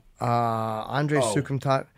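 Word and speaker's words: Andre Soukhamthath: Andre Sukumtat. Am I saying it right? – Yeah, you got it.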